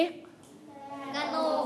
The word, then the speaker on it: กนก: กระหนก